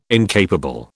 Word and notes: incapable: British English

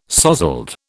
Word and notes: sozzled: British English

